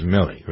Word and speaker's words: Milley: is it Milley or Milley? I never pronounce his name. – Milley. – It's Milley, right.